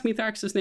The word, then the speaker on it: Mithrax: How do you pronounce Mithrax's name again? This is Mithrax. – The fallen, or the Elixni, call him Mithrax. Like with an S. – Okay, so with the TH is the wrong one.